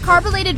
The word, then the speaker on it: carbolated: DL, I heard you mispronounced carbolated Vaseline, and you ended up saying carbonated Vaseline, and swore you were saying it right.